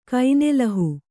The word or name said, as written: ಕೈನೆಲಹು